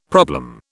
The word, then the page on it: problem: British English